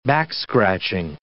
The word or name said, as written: back-scratching